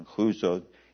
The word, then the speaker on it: Chuza: And Joanna, the wife of Chuza, I think the CH should be a rough H pronunciation, Chuza, Herod's steward, and Susanna, and many others who provided for him from their substance, from their possessions.